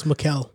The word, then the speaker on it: Mikal: In the comments, they said, I said his name wrong, his first name wrong. Well, it's Mikal.